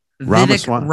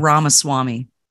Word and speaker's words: Ramaswamy: Ramaswamy. Ramaswamy. – Ramaswamy.